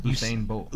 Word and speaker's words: Usain Bolt: Usain Bolt.